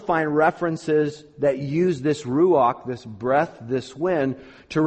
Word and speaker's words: ruach: Well, like the Greek word, the Greek synonym, this Hebrew word, it's a fun word to say, because you got to get that real guttural sound at the end. It's ruach, ruach, ruach.